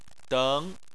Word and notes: deng: 2

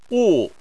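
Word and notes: wu: wu4; tone 4

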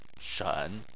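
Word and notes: shen: tone 3

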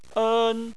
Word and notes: en: 1

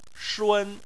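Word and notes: shun1: tone 1

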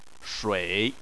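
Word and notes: shui3: tone 3

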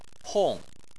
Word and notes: hong: 4

